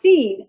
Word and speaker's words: Sin: And it says, okay, the sin in the wilderness of sin is merely a transliteration of the Hebrew word pronounced seen, S-E-E-N, which means thorn.